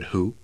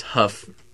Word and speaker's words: Hough: How do you know it's not David Who? – Because it says so in his books. – It's spelled like dough. – It says Hough, pronounced like rough.